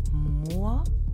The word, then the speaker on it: Moor: Auf Deutsch gibt es das Wort Moor und das Wort Möhre. Die hören sich ein bisschen ähnlich an. Aber wenn du mal genau zuhörst, merkst du, dass sie doch ziemlich unterschiedlich ausgesprochen werden. Hör mal. Moor, Möhre. Hörst du das? – Das hört sich für mich gleich an.